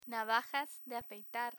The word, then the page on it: navajas de afeitar: spanish